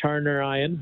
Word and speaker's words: Harnarayan: It is pronounced Harnarayan, but yeah, there is an extra A in there that throws it off.